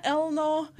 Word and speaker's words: Eleanor: My family are working class Scottish people, so it's actually pronounced Elna. – Jodelina Elna.